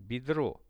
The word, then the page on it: bedro: Russian